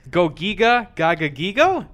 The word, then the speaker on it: Gogiga Gagagigo: Okay, for those at home, it's spelled G-O-G-I-G-A space G-A-G-A-G-I-G-O. Gogiga Gagagigo?